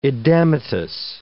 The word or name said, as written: edematous